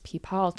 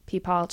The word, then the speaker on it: pipal: In the 3rd and 4th century BC, we get silver coins where we see images of these unicorns with sacred, I believe it's pronounced peepal trees, P-I-P-A-L, peepal trees,